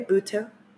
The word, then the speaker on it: Buteau: I'm also thinking of Michelle Buteau. I think that's how you say her name. Yeah. – I haven't seen her doing stand up. – B U T E A Buteau.